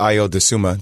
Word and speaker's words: Ayo Dosunmu: I thought Ayo Dosunmu, that's how you say his name.